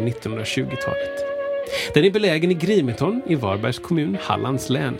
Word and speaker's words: Grimeton: Radiostationen i Grimeton uttalas Grimeton är ett industriminne som sedan 2004 står på Unescos lista över världsarv.